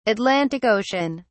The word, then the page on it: Atlantic Ocean: British English